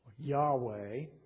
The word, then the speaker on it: Yahweh: You know that when you see that word Lord in capital letters, that the original Hebrew is actually the word Yahweh. We think that's the correct pronunciation. I say we think because it's just consonants. There's no vowels. But later scribes of the Hebrew Scriptures plugged in vowels, and the best we can determine is probably pronounced Yahweh.